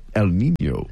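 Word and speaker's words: El Niño: El Nino.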